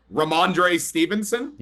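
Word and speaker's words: Rhamondre Stevenson: Ramondre Stevenson.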